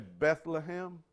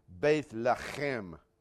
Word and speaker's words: Bethlehem: By the way, Bethlehem in the Hebrew is actually pronounced Bethlehem. Can you say that? Bethlehem.